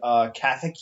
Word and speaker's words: Kathak: There you guys find Kathak Yosuke.